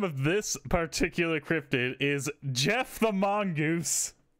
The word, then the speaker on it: Gef: Jeff the Mongoose. – It's just like a man name. I knew it. – But it's spelled G-E-F.